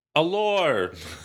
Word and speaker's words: alors: The S is silent, by the way. – Alors.